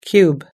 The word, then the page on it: cube: American English